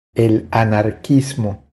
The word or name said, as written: El anarquismo